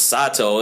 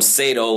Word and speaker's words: Sato: Sato, he's Japanese, should be pronounced Sato. They call him Sato like he's into sadomasochism. I whip my bitches.